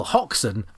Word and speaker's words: Hoxne: Well, Hoxton, as I'm sure you know, is a place in Suffolk. – Obviously. – Which is spelled Hoxne.